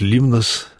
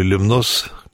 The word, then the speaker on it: Лемнос: и остров Лемнос, который греки называют Лимнос, казаки говорили Лемнос, как произносили французы.